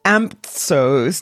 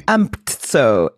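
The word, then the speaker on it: AMTSO: Amptso, A-M-T-S-O. I don't know how you'd say it, but... – It's more of a silent T.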